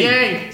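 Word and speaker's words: Ye: Bij uh, eh, je, first, je, hoe spreek je dat uit? Jee. – Jee, Jay.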